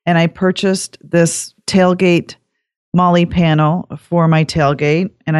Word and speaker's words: Molle: So, and now my other show and tell is we were talking about it's actually pronounced Molly, which, CPO informed me of that.